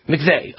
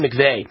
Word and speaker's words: McVie: And I know he's listening right now, or I'm assuming he's listening, because he knew I was talking about this Christine is it pronounced McVie of Christine McVie or McVeigh? McVeigh of Fleetwood Mac. Christine McVeigh, who I believe was, you know, a fantastic vocalist and keyboardist for Fleetwood Mac.